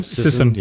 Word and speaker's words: Sisson: Like Mark Sisson, is that how you say his name? – Sisson.